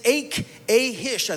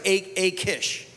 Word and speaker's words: Achish: He goes to an enemy king whose name is Achish, I think is the way you pronounce it actually in Hebrew that way. But as we look at it, it looks like Achish.